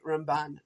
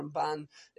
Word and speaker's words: Ramban: So if it's the Rambam, emphasis on the first half, as opposed to the Ramban, emphasis on the second half.